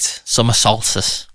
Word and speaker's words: Solstice: Summer Solstice. – No, Summer Solstice. – Oh, see, I just say it really fast and get away with it. – Summer Solstice. Think of salt.